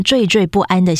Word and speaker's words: 惴: 这应该是对未来前途感到茫茫然，以及在经济考量上萌生了一种惴惴不安的心绪。来，我们教一下成语哈，惴惴不安。有些人可能容易会把这个字念成喘，或者是端，啊，就是端正的端，还是喘气的喘？